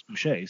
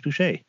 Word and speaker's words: Boucher: It is Chris Boucher, so. – Yeah, but I'm anglicizing it for our audience. Guess toi. – That's how he pronounces his name. Like, that's how NBA announcers announce his name.